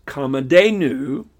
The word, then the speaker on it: Kamadhenu: And also, there's another deity, and the pronunciation is Kamadhenu. That's K-A-M-A-D-H-E-N-U, Kamadhenu.